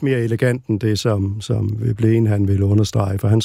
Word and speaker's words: Veblen: Og det ligger der i Veblens... han var egentlig en norsk ekstraktion, så jeg tror, det er Veblen. – Ja, det er udtalen.